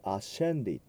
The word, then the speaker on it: ascendit: スブポンツィオピラートパスス・エット・セプルトゥス・エスト・エット・レゾレクシット・テルツィア・ディエ・ secundum scripturas et ascendit。はい、この、 SCE という音は CE だけだとチェになりますが、前に S がつくのでシェという発音になります。